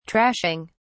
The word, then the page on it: trashing: British English